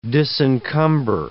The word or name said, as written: disencumber